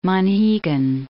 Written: Monhegan

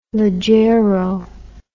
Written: leggiero

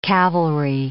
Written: cavalry

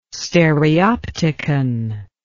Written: stereopticon

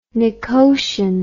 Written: nicotian

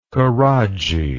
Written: koradji

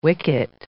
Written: wicket

